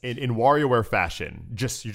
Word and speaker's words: WarioWare: You got to say it right. – I know. WarioWare.